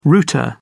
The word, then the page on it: router: British English